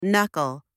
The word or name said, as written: knuckle